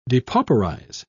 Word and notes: depauperize: British English

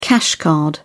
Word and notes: cash card: British English

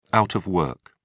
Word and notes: out of work: British English

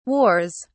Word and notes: wars: British English

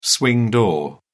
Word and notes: swing door: British English

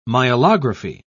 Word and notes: myelography: British English

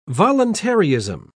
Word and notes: voluntaryism: British English

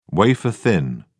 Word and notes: wafer-thin: British English

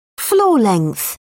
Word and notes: floor length: British English

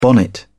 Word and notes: bonnet: British English

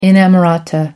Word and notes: inamorata: American English

